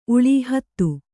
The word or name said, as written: ಉಳಿ